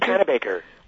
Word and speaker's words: Panabaker: Panabaker. – Panabaker.